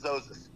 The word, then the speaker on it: Zozus: It's Zozus.